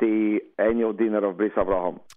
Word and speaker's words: Avraham: You had to make underline, because when God says to Avraham, Avraham, then we want to make sure that everyone pronounces the proper way, Avraham.